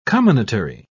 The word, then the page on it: comminatory: British English